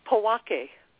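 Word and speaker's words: Pojoaque: It's Powake. – Powake.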